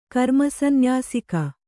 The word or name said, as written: ಕರ್ಮಸಂನ್ಯಾಸಿಕ